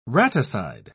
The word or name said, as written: raticide